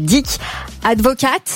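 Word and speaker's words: Dick Advocaat: Donc, alors, j'ai peur de mal le prononcer. Dick Advocate. – Oui, c'est bien.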